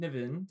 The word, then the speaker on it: Niven: It won Best Actor for David Niven.